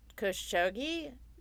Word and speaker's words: Khashoggi: Khashoggi? – Yeah. – Is that how you say his name? I've never said his name out loud. I've only ever read it. – No, that's pretty good, yeah.